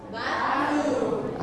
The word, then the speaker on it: bathroom: Bathroom. – Okay. Don't forget the th sound. You have to use your tongue.